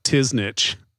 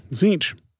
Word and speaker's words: Tzeentch: My favorite mispronunciation of that one when I worked at the hobby shop was when kids would call it Tiznitch. – Yeah. For those not in the know, I think I got pretty close considering it's spelled T-Z-E-E-N-T-C-H. So, Zinch.